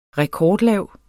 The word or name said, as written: rekordlav